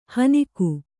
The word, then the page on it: ಹೆಣ್ಣುಗ: Kannada